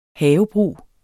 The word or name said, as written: havebrug